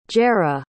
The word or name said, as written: jerrah